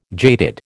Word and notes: jaded: American English

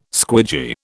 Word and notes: squidgy: British English

